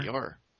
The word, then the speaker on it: Dior: Dior.